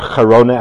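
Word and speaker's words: Girona: So in the early part of the 13th century, from the city of Gerona, or Gerona as it's properly pronounced, goes a very unique individual whose name is Rabbeinu Yonah ben Avraham.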